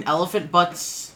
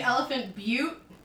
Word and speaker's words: Elephant Butte: It's actually Elephant Butte. But because Butte is spelled Butt, Noah's calling it Elephant Butt.